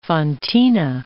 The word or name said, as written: fontina